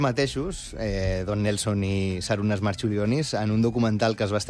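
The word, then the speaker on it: Marčiulionis: Jo crec que li diuen Marciulionis, però... Perquè aquesta C es pronuncia com una TX.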